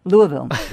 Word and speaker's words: Louisville: Louisville. Yep. That's where I was. Thank you. And now I don't have to say it again. – Louisville.